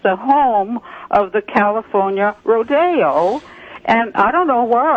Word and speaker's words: rodeo: They all say rodeo, and it's not correct. – Well, gosh, you know, I've only known rodeo as Rodeo Drive in Beverly Hills, but in every... – Well, that's the correct pronunciation. It's a Spanish word the E's pronounce as an A.